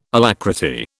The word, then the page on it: alacrity: British English